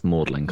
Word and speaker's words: Magdalen: Although I, I will point out, it's Magdalen. It's spelt Magdalen, but it's pronounced Magdalen College. Magdalen, okay.